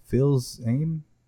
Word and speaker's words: Fils-Aimé: Fils-Aimé, Reggie Fils-Aimé.